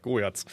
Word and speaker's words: Goyatz: Äh, also geschrieben wird es G-O-Y-A-T-Z Goyatz. Oder spricht man das O-Y wie Goy aus? Goyatz.